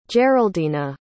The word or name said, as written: geraldina